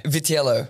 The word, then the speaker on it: Vitiello: How do you say? – Vitiello. – Vitiello. – Yep. – Italian? – Yep.